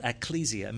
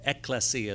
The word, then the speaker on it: ecclesia: Ecclesia means called out. Or excuse me, ecclesia is the proper pronunciation.